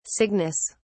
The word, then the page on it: cygnus: British English